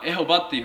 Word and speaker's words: ヤーウェ: でおそらくはっきりとしてないんですけども、ヤーウェっていうふうに言われるのが正しいんじゃないか、正しい発音じゃないかというふうに一般的に言われています。中にはエホバっていうふうに発音する人たちもいます。